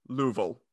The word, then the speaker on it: Louisville: Have you been in Louisville? Or sorry, it's two syllables. Louisville. – Louisville.